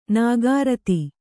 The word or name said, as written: ನಿಕೃತ